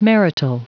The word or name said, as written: marital